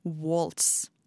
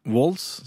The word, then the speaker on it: Waltz: avskjedskonsert som de kalte for The Last Waltz. – Walls, sånn som i vals? Ja. ja. – ja. Skal man ikke uttale T-en? – Jo, jeg, jeg, vet ikke.